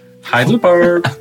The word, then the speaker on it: Heidelberg: Heidelberg. It's German. Heidelberg. Name's German. – Uh-huh. And it makes me feel like yodeling, but I'm not a good yodeler. – Pronunciation, actually, Heidelberg. – Okay. – Because it's German.